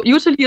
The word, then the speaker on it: Youtilly: Erstmal habe ich euch ja Utili, nein, du sprichst es Utili aus und ich habe äh, Utili, ne, wie habe ich gesagt? – Ich glaube, du hast schon ganz richtig gesagt. Ja. Also Utili ist schon richtig, ja.